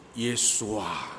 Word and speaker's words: Yeshua: The correct pronunciation should be Yeshua.